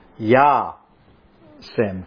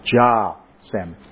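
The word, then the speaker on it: yasem: When I studied Hebrew at Shalom College, that would be pronounced in a Sephardic way as Ya-sem. But in the Western Christian dialect of Hebrew I now use, that's pronounced Ja-sem. Now, a cal verb, spelled K-A-L, is just a simple basic verb without any special nuance.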